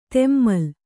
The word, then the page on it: ತೆಮ್ಮಲ್: temmal